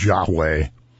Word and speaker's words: Yahweh: And that is Lord all capitalized, which in an English Bible, when Lord is all capitalized, means God's name, which is Yahweh. At least that's how we pronounce it. It's four consonants with no vowels, so it can't really be pronounced.